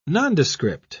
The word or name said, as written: nondescript